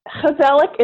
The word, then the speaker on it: gezellig: So it's G-E-Z-E-L-L-I-G. So here it looks like it says gezellig, but it's a Dutch word. And in Dutch, that G, instead of it being a G, it's a H, like an H.